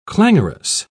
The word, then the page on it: clangorous: British English